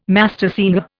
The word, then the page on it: mastersinger: Indian